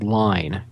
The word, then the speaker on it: Lyne: Okay, my pick is a game called Line, and it's spelled L-Y-N-E.